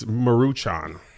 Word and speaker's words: Maruchan: Y'all prefer Top Ramen or Maruchan? – It's Maruchan. You added all type of letters.